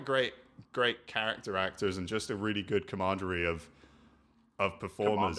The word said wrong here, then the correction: camaraderie: I don't, how do you say it? – Camaraderie. – Camaraderie, yeah. Camaraderie. – Right.